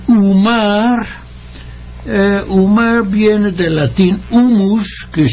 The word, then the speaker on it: humus: Humus, yo pronuncio a veces en con la H aspirada, porque es una palabra casi internacional, aunque procede del latín.